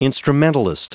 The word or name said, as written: instrumentalist